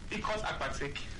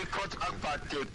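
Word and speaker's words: Ikot Akpatek: Ikos Akpatek. – Ikos Akpatek in Una local government area for Kwebon State. – Okay. – Ikos Akpatek community in Una local government area.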